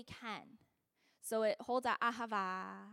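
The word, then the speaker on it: hava (אהבה): that the aleph is actually a silent letter. So actually when we say ahava, and I say it with a horrible Michigan accent, sorry. But it's actually a silent letter. So how it's really pronounced is hava, right? You breathe in. You're actually breathing in that first letter, hava.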